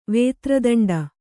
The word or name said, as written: ವೇತ್ರದಂಡ